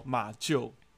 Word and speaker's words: 马厩: 把他的喇叭锁拆拆坏，真的是，我看一下红尘迷途小书童说读音念旧哦，马厩。